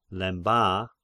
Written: Lemba

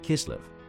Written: Chislev

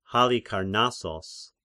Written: Halikarnassos

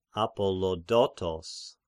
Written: Apollodotos

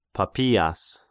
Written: Papias

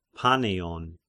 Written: Paneion

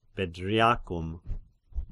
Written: Bedriacum

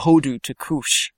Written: Hodu to Cush

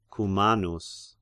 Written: Cumanus

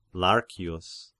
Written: Larcius

